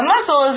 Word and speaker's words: muscle: Now, a muscle, like Elsie showed us earlier, a muscle is a part of the body that contracts or gets tighter and helps produce movement. It's spelled M-U-S-C-L-E, muscle. It's got that strange S-C there.